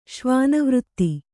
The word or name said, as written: ಶ್ವಾನವೃತ್ತಿ